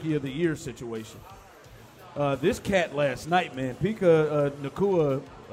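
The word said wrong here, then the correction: Puka Nacua: I'm sure that I'm butchered that in some form, but Puka Nakua, excuse me, Puka, this cat here, 9 catches, 160-plus yards.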